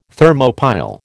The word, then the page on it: Thermopile: American English